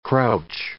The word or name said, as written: crouch